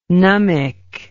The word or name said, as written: Numic